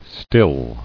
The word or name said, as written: Stilled